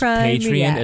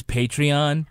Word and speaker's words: Patreon: Slash he mispronounces Patreon as Patreon.